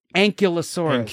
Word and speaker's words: Ankylosaurus: Ankylosaurus. – Ankylosaurus? – Ankylosaurus? I don't know. I'm staying neutral on this. – I remember as a kid, I always thought it was ankylosaurus.